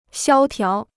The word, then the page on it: 萧条: xiāo tiáo